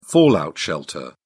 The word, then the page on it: fallout shelter: British English